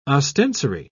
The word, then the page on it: ostensory: British English